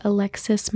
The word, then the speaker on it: Alexus: As I mentioned in the introduction, my name is Alexus Mariah. Alexus is spelled a little different, so it's A L E X U S, like the car.